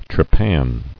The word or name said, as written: Trepanning